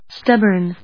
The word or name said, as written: stubborn